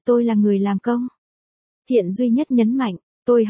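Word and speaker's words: Thiện (单): Tôi tên là Thiện duy nhất, Thiện là từ đa âm, dùng làm họ thì đọc là Thiện, không phải là Đan trong đơn giản.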